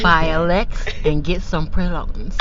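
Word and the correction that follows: praline: Did I say that right? – I don't think so. Close. Close with no cigar. – Miss Stephanie, sure how to say it. – Pralines. Buy a Lex and get some pralines.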